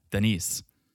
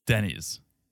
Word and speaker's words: Denny's: Denny's, for you who are new to the podcast, she thinks the proper pronunciation of America's diner is Denise. – Denise. – Denny's.